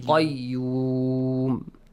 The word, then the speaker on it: قيوم: قاف has to be pronounced heavily. قيوم.